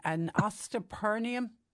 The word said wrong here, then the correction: osteospermum: Nearly, osteospermum.